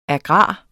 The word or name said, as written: agrar